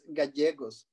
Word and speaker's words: Gallegos: My name, my last name is Gallegos.